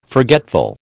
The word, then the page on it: forgetful: American English